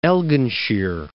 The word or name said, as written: Elginshire